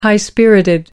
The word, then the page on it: high spirited: American English